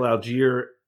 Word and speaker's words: Allgeier: But then I also want to get your thoughts on Atlanta drafting Tyler Allgeier, if I pronounce that correctly. – Sure did. – So first, let me tackle Allgeier.